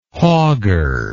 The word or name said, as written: hogger